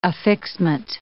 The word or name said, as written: affixment